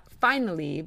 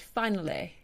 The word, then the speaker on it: finally: And also, the Y sound is not an E sound, so the word finally is not finally, but finale.